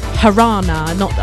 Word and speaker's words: Jarana: Previously, we had Jarana. Actually, that's pronounced Harana. I don't pronounce the J.